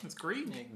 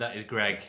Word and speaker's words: Greig: Is it Greg or Greg? – I mean, it says it's spelled Greg. – Is it? – Greig, that's Greg. – It's Greg. – Greg. – In Scotland, that is Greg.